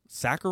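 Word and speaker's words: Sachar: And if you are a teacher, you might want to go there and Sacker rhymes with cracker.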